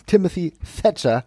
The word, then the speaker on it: Timothy Thatcher: Walter und Timothy, Timothy Thatcher. – Ja. ja richtig ausgesprochen jetzt. ist manchmal ein wenig schwierig. Das TH, meine Lieben.